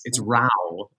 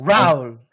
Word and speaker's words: Raoul: Yeah, apparently, it's been I don't think Raoul, but it's Raoul, I guess. – Raoul, with the little French pronunciation, I guess.